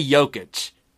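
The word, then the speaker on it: Jokić: I know he's Soft J Jokic.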